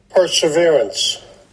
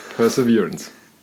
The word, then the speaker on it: Perseverance: Ich glaube, die häufigsten Alternativen war Perseverance und Preservance, die ich gehört habe. Ja. Aber für alle, die es uns nicht glauben, dass wir es richtig aussprechen, hier kommt einmal so klingt es.